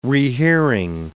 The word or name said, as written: rehearing